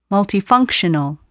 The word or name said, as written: multifunctional